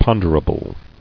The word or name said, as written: Ponderability